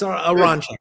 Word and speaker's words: Aronchick: No, you're very close. Aronchick.